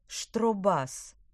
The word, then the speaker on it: штробас: Давайте познакомимся с таким инструментом, как штробас, ударение на «а».